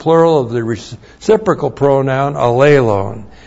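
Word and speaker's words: allelon: When it's used with the reciprocal pronoun alelon, alelon looks like that. A-L-L long E-L long O-N. Alelon.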